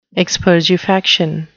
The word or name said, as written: expergefaction